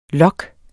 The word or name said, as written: lok